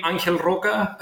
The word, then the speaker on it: Angel Roca: I think if you look for my name, Angel Roca, maybe it's easier to get that.